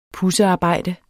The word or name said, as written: pudsearbejde